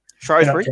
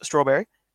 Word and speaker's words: Shrewsbury: Shrewsbury? Or as Aiden Hazard once pronounced, Strawberry? – Strawberry Town, yeah.